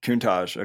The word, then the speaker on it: Countach: Real quick, as far as your music and your projects that you have with Jethro, and then how do you pronounce it? Countach or Countach? – Countach. Okay. – Like the Lamborghini.